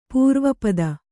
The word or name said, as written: ಪರಿವಾಪಿತ